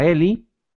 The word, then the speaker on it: Heli: pues tenemos a Eli. Eli, no Heli, favor de no confundir, yo me confundía mucho con la pronunciación como con Haley, que bueno, esa sí era Haley, no era Ale.